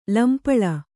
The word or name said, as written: ಲಂಪಳ